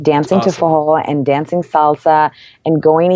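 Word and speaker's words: forró: It's spelled F-O-R-R-O, but it's pronounced fajol.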